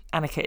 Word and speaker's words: Anneka: Annika is spelled A-N-N-E-K-A.